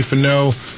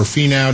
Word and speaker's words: Finau: Tony Finau, or Finau, depending on your pronunciation, is 8 under.